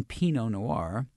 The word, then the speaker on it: Pinot Noir: Pronouncing the T in Pinot Noir.